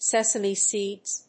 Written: sesame seeds

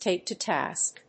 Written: take to task

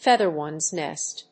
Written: feather one's nest

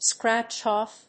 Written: scratch-off

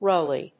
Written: roily

